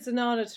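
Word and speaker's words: Zenana: Oh, when you said Zanana to rhyme with banana, I felt like that had been explained.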